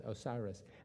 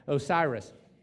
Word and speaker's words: Osiris: And then the last one is a judgment over Osiris, there it is, Osiris. I think that's the first time I pronounced that correct today, Osiris.